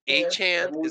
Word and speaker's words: Achane: Devin Achane, is that what we said? – Yes, good job.